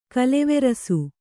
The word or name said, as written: ಕೋಲುವರಸೆ